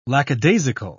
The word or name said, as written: lackadaisical